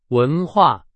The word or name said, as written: 文化